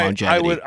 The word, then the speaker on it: longevity: The letter G is pronounced twice in longevity because the word contains two different G sounds. The first is a nasal G sound from long, and the second is a J sound like the J in jeans or giraffe.